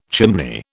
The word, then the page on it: chimney: British English